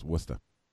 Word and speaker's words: Worcester: You're saying it wrong. It's Worcester. Or as my boss says, Worcester.